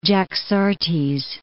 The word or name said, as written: Jaxartes